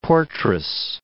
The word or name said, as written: portress